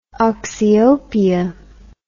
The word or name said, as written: oxyopia